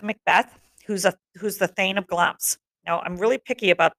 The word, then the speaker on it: Glamis: So if you look at the spelling of Glamis and you see how I'm saying it, they don't match. I want it pronounced Glamis because that's correct.